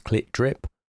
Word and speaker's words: CLTDRP: The opening track of their album announces that the band name is pronounced Clit Drip. Obvious, I guess. It's hardly going to be Clop Droop.